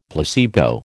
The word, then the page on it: placebo: American English